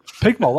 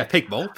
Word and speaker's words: PGMOL: Pigmol, that's how you say it, isn't it? – That's how you say it, yeah, Pigmol. P-G-M-O-L. How else would you say it? Pugmol.